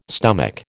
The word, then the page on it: stomach: American English